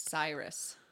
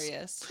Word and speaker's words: Sirius: When I was really little, I for some reason thought Sirius was Cyrus. Ooh. And I don't know, because it's totally spelled Cyrus, like it would be pronounced virus. Sirius.